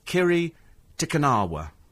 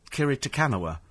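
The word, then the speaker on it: Kiri Te Kanawa: On the television this morning, somebody pronounced it Dame Kiri Takanawa. And I listened to it, and I thought, no, it's Kiri Takanawa. That's how you pronounce it.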